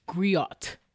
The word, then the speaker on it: The Griot: Am I saying that right? – Griot? Yeah, The Griot. – I always like want to say Griot. Like, no. Griot. – No, I think it's Griot. – Griot. And The Griot.